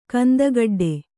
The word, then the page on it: ಕಂದಗಡ್ಡೆ: kandagaḍḍe